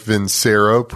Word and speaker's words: Vincero: That's Vincero, V-I-N-C-E-R-O, spelled like Vincero, pronounced like Vincero.